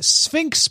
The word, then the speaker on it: SPHINCS: And then Sphinx Plus, but it's spelled S-P-H-I-N-C-S Plus,